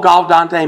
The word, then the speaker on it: Gaudete: All Gaudete means, and am I close to pronouncing that right, my Latin scholar? Close enough.